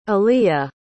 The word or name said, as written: eliya